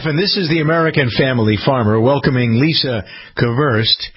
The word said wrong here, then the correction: Keverest: It's Keverest. I got to make sure I pronounce the name correctly.